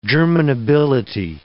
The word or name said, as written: germinability